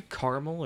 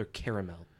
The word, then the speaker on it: caramel: Caramel is like one syllable. Caramel. Caramel is two syllables. Caramel. What? Caramel. It's three syllables.